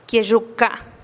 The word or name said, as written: kie'xuka